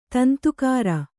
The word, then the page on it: ದುಂದುಗಾರ: dundugāra